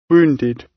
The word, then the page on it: wounded: British English